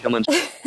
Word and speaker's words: Kilimanjaro: How do you say it? Kilimanjaro? – Oh, that's, yeah, it's perfect. Kilimanjaro. Exactly like it's spelled.